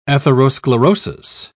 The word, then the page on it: atherosclerosis: British English